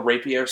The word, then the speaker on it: rapier: They had their little rapier sword, the really thin one. – How do you say that again? – Rapier. – It's a rapier. – R-A-P-I-E-R. I'm fairly certain.